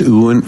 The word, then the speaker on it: Ewan: And the actor that plays Mick, I always say his name wrong. It's Ewan.